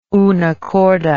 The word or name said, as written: unacorda